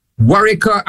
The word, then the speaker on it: Warika: Warika, and it's a little roadway going up to the hill where I lived.